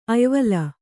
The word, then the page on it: ಅಯ್ವಲ: ayvala